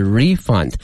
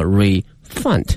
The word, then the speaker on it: refund: 那么刚才我们举的这三个例句呢，都是呢作为名词来使用，它的发音是 refund。那么接下来我们看看它的动词的这个使用，它的发音也发生了变化，叫 refund。好，看下面的例句， We guarantee to refund your money if you're not delighted with your purchase。